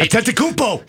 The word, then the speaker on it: Antetokounmpo: Giannis Antetokounmpo. Did I say that right? – Antetokounmpo.